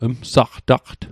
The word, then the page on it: msaqtaqt: Mi'gmaq